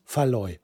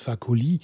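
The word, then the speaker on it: Fakoly: Und nicht Faloy. Faloy ist völlig falsch. Was ich immer gesagt habe, warum auch immer, habe ich ihn immer Faloy genannt. Aber er heißt Fakoli.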